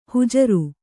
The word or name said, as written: ಹುಜರು